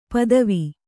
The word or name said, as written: ಪದುವ